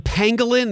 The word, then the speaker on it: pangolin: The pangolin, that's how you pronounce that? It's like a little scaly animal? – Correct.